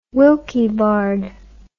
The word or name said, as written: Wilkie Bard